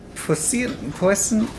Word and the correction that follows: puissant: I'm not sure to pronounce that word. – Puissant? – Puissant? Perhaps. – Yeah, it's puissant. Puissant.